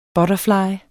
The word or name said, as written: BUTTERFLYENES